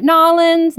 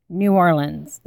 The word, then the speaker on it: New Orleans: New Orleans. New Orleans. Not Nollins, not New Orleans. It is New Orleans. That is the way the locals say it.